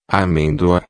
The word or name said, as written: amendoa